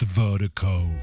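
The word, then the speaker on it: Virdiko: And Vertigo is spelled V-I-R-D-I-K-O. D-I-K-O. That's V-I-R-D-I-K-O. Pronounced Vertigo. It's the global music promotion.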